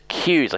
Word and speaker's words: Kuys: Uh, Melissa Keys is the one to. Oh, pardon me, Melissa Kuys.